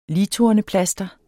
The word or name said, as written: ligtorneplaster